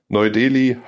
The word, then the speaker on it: Neu-Delhi: Neu-Delhi, Hyderabad und Bangalore.